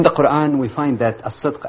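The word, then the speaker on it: as-sidq (الصدق): Well, your pronunciation was close enough. It's as-sidq. Yes. As-sidq, which is translated more or less in English as truthfulness.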